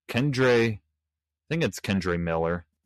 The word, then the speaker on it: Kendre Miller: Kendre Miller, that's how you say it, from the New Orleans Saints.